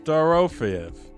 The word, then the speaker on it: Dorofeyev: D-O-R-O. So D-O-R-O. – Okay. – Fayev. – Pavel Dorofeyev. – D-O-R-O-F-E-Y-E-V.